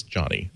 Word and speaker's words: Jony: I had an old friend who Jony, who pronounced it Joni, and so I tend to just reflexively say Joni. I've been told it's Johnny. – Yeah, Johnny Ive.